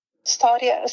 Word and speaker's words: Storia: Am I saying that right? – Storia, sì, una lunga storia d'amore.